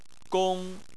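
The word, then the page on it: gong: tone 1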